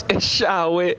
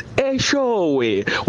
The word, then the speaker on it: Eshowe: It's not Eshawe, it's Eshowe. When you say Eshawe, direct translation means somebody should get beaten up.